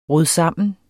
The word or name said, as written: rodsammen